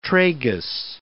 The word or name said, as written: tragus